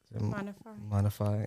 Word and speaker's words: Maana Faay: Did I butcher it? – Maana Faay. Yeah.